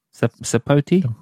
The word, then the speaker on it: sapote: It's spelled S-P-S-A-P-O-T-E. How are we pronouncing that? – S-A-P-O-T-E. Yeah. Sapote? Sapote.